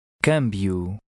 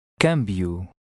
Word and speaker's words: câmbio: Câmbio. – The circumflex over the A indicates that the A has a slightly closed sound and the stress falls on that syllable. Now you read number 9. – Câmbio.